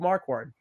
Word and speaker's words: Marquard: And then we have the newcomer, who Josh mentioned and pronounced correctly, Rube Marquard.